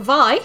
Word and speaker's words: Vay: But tonight, the first game we're looking at is called Vi. It's spelt Vay, V-A-Y, also known as Vay Ryusei no Yoroi, Armor of Ryusei in Japan.